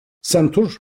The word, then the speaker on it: santur: Bu çalgı Türkçe'de santur diye telaffuz ediliyor ama birçok yerde Batı dilleri esas alındığı için A olarak yazılıyor ve böyle okunuyor. Oysa Farsça alfabeyle yazımında senturdur, yani santur değildir. Sentur, kanun gibi yatay olarak çalınan telli bir çalgı.